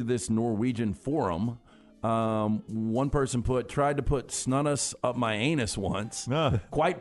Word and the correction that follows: snus: Chris says it's pronounced snus. – All right. – That's the way they say it over there.